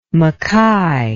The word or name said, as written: makai